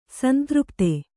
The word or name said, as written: ಸಂತೃಪ್ತೆ